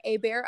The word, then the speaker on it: Hebert: It's at Chesney Claire Hebert, I believe, and that's spelled like Chesney Claire and then H-E-B-E-R-T.